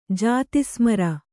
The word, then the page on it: ಜಾತಿಸ್ಮರೆ: kannada